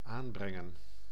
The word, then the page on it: application: [ˌæ.plə.ˈkeɪ.ʃən]